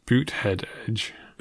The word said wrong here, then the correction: Buttigieg: That's pronounced Buttigieg. – It's pronounced Buttigieg.